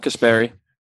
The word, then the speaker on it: Kasperi: Yep, Kasperi.